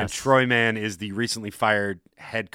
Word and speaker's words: Troy Mann: to say it like Florida Man. Troy Mann.